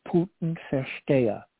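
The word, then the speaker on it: Putinversteher: There's a term in German which is pronounced Putin-Verstehe.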